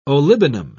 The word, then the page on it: olibanum: British English